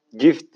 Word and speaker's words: gift: Boş konuşmak da benim Allah'tan bana verilmiş, eşime verilmiş bir e, cift olduğunu düşünüyorum. Cift bu arada İngilizce hediye, armağan gibi düşünün yani. Gift diye okunuyor diyor Mutlu.